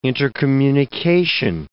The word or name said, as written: intercommunication